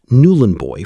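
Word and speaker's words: Nhulunbuy: Newlandby, pronounced Newlandboy for the uninitiated, though the uninitiated were relatively few, sits on the Gove Peninsula in the far north of the Northern Territory.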